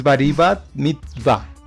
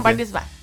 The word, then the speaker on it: Bar Mitzvah: Es Baribat Mitzvah. ¿Qué? Bari. – No creo que se diga así. No sé cómo se pronuncia. El Bar Mitzvah. – Según ya dicen Bar Mitzvah.